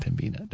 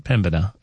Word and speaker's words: Pembina: It's not Pembina. Don't do that. Pembina.